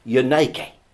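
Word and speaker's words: Unike (Εὐνίκη): You see, the name Eunice in the Greek is actually pronounced Unike.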